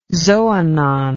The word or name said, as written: xoanon